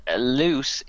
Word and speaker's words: Louse: And Luce is spelled L-O-U-S-E, but he insists it is pronounced Luce. – Not Louse. – No, I think you'll find it's Luce. – Or L-O-O-S.